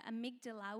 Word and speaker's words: amygdala: And so in our brains, there's something that, you've got to help me, I never know how to say this, it's the amygdala, which is the fear center of your brain.